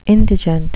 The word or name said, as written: indigent